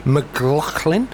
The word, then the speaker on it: McLachlan: McLachlan.